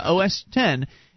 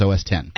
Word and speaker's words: OS X: I think it's yeah, OS 10. It's written as X. – Yes, I understand, but I have always heard people pronounce it as OS 10.